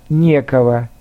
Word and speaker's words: некого: И, наконец, к безличным предложениям относятся предложения с отрицательными местоимениями и наречиями, как «некого», «нечего», «не о чем», «некогда», «некуда», с ударением на первом слоге.